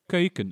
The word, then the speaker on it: Keuken: Keuken, in de Keuken.